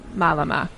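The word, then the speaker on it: mālama: Kalei, let's talk about the Hawaiian term malama. Am I saying it correctly? Malama? – Malama.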